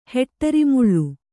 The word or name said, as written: ಹೆಟ್ಟರಿಮುಳ್ಳು